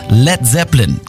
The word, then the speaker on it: Led Zeppelin: Led Zeppelin. Das A in Led ließ er einfach weg, damit es nicht wie Blei ausgesprochen wird.